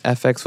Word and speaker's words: Ephex: And so eventually I'm like, okay, like no one can pronounce it because it was spelled like E-P-H-E-X. So if I was on the phone with you, you'd be like, oh, how do you spell that? Is it F? Is it S?